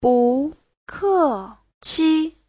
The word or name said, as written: bu2ke4qi5